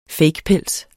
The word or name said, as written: fakepels